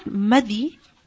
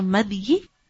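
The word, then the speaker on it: مذي: Now the word madhi, this is pronounced in 2 ways. It's pronounced with a sukoon on the dhal, so madhi, and it's also pronounced as with a kasra under the dhal, so as madhi. Both are correct.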